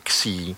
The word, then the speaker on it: Xi: To se v anglické literaci vyslovuje jako new a to přišlo jako, že by to vypadalo, že ten nový virus, což není. Další písmeno v řecké abecedě, ten následovalo je XI, což se v anglické literaci vyslovuje jako C.